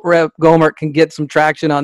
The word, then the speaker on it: Gohmert: Go- Gohmert, I don't know if I'm saying it right, G O H M E R T, Gohmert, calls for Congress to ban Democrats due to the past position on slavery.